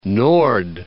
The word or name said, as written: Noord